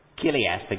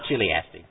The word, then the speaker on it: chiliastic: So, just to add to your vocabulary, and you pronounce it with a K. It doesn't have anything to do with meat and chilies and stuff. It's Kiliastic, not Chiliastic.